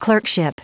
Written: clerkship

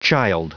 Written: child

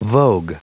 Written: vogue

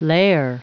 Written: lair

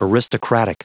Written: aristocratic

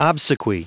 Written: obsequy

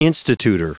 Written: institutor

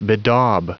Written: bedaub

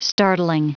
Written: startling

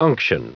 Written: unction

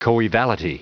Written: coevality

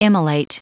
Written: immolate

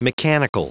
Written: mechanical

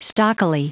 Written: stockily

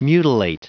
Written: mutilate

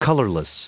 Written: colorless

